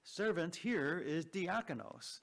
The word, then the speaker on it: diakonos: That word, servant, is diakonos. And I'll spell that for you. It's D-I-A-K-O-N-O-S. D-I-A-K-O-N-O-S.